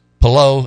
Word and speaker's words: Palou: Palou is the way that's pronounced.